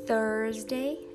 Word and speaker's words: Thursday: Então, a pronúncia de Thursday é com o som do TH, diferente de Tuesday.